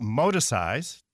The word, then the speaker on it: Moticise: I know that you have a special term that you're using for it called modicize.